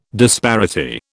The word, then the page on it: disparity: British English